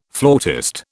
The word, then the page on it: flautist: British English